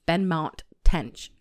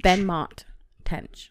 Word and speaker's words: Benmont Tench: Benmont Tench, I don't know why I can't say his name, is Benmont Tench, and it's hard to say. – Benmont Tench.